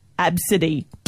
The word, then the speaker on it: Abcde: A, B, C, D, E, pronounced Absidy.